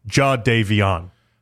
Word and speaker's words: Jadeveon: And then I was like, and so I have to, every time I say Jadeveon Clowney, I have to actually sound it out in my head, Jadeveon. – I think, because you said it right again, I lost how I also used to mispronounce it.